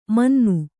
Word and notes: ಮುನ್ನಿ: munni

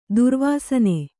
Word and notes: ದುರ್ವಾಸನೆ: durvāsane

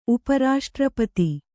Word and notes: ಉಪರಾಷ್ಟ್ರಪತಿ: upa raṣṭrapati

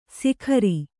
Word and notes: ಸಿಖರಿ: sikhari

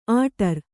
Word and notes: ಆಟಾರು: āṭāru

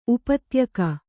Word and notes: ಉಪತ್ಯಕೆ: Kannada

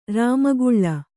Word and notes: ರಾಮಕಲಿ: Rāma kali

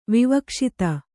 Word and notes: ವಿವಕ್ಷಿತ: vivakṣita; Kannada

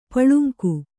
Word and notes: ಪುಲ್ಲಿಂಗ: Kannada

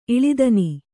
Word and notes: ಇಳಿದನಿ: kannada

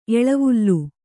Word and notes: ಎಳೆವಲೆ: eḷevale

